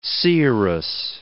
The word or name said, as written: seeress